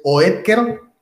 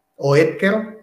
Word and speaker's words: Oetker: que es Oetker, pues se escribe con K, Oetker, ajá, Oetker, este,